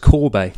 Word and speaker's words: Kobe: We're going to start off by talking about England's 45-7 win over the USA in, I believe it's pronounced Corbe. – Yes. I mean, I think everyone I've heard over the last sort of, at the end, since the final whistle of the Tongi game to this one, has called it Kobe City. – Kobe City, but Nick Mullins on the ITV commentary said this is how you pronounce it. So yes, you are right.